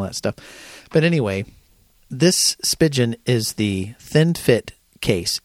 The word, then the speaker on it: Spigen: It's spelled S-P-I-G-E-N. And I say Spigen. I don't know why, because it's kind of like a pigeon.